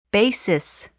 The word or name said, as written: basis